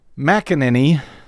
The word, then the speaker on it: McEnany: His Trump's press secretary, Kayleigh McEnany, I guess, still don't know how to say her name. – That's close enough.